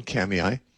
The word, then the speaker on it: Kamiah: Now, if you're in central Idaho, I'm going to be speaking in a small town, Kamiai, in the Clearwater Valley, which if you look up on a map, it doesn't look like it should be pronounced Kamiai. It looks like it should be pronounced Kamiah. It's K-A-M-I-A-H.